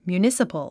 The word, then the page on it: municipal: mjuːnísəpəl; ミューニサパル